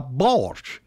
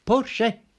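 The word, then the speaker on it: Porsche: ورغم أن كثيرين ينطقونها بورش، فإن اللفظ الألماني الأقرب هو بورشه.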